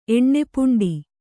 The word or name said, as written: ಎಣ್ಣೆಪುಂಡಿ